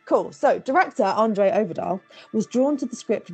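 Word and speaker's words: Øvredal: Andre Oberdahl. – It is just Oberdahl. Okay, it's because the O with the slash through it and it threw me.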